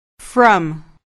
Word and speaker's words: from: From. – دقت کنید که برای تلفظ این کلمه نگوید فرام، باید بگوید فرام، یعنی حرف F و R باید به صورت سریع و بدون فاصله بیان شوند.